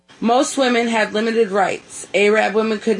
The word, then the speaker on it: Arab: Yep, it did vary in your Arab society. Apparently, she's been to North Central Alabama. – That's where you pronounce A-R-A-B is Arab.